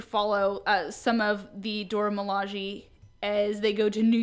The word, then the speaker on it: Milaje: To focus on T'Challa's bodyguards, and I hope I remember how to pronounce this, the Dora Milaje? – Milaje, that sounds right.